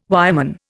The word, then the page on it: wireman: Indian